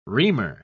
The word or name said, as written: reamer